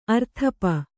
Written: ಅರ್ಥಪ

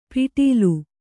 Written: ಪಿಟೀಲು

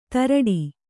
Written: ಧಾರಾಟ

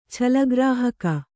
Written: ಛಲಗ್ರಾಹಕ

